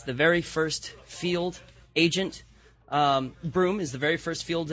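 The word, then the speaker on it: Bruttenholm: And Brunholm, Broom, as it's pronounced,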